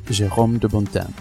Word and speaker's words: Jérôme de Bontin: Today's guest is Jérôme de Bontin.